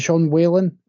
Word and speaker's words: Sean Whalen: Is it Sean Whalen? Is that how you pronounce his name? – Yeah.